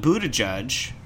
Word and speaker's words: Buttigieg: There was a meeting with some Democratic leaders, I think including Pelosi and Pete Buttigieg, former mayor of Indiana. – Is that how you pronounce that? – Yes, former boy mayor.